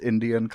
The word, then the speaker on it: NDN: as you know it's spelled ndn but it's pronounced indian collective